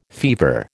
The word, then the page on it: fever: American English